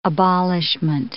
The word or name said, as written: abolishment